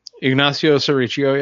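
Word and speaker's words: Ignacio Serricchio: But then we got Ignacio Sericcio. Saying that right? – Ignacio Sericcio, yeah.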